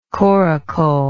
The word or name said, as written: coracle